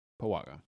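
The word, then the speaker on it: Paguaga: You said P-U-A? – P-U-A, guys, no. – It's like been 1 month of baking. – P-A-G-U-A-G-A. P-A-G-U-A-G-A. Pawaga.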